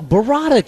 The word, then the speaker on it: burrata: You never heard of Bovada cheese? Is it Bovada? – No, burrata cheese.